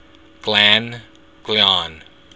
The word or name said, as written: gleann